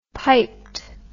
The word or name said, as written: piped